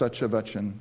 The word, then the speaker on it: Satyavachan: And I want to tell you something about this church, Satyavachan, and it's actually spelled S-A-T-Y-A and then V-A-C-H-U-N, but it's pronounced Satyavachan.